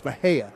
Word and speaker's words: Mexia: Mexia. – Did everybody just pronounce it wrong? No, it's Mahia. – Well, if you're not from Texas, you wouldn't know that.